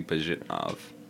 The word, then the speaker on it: Pajitnov: That's Alexei Pajitnov. I think is how you pronounce his name. I know it's Alexei. I just don't know about the last name.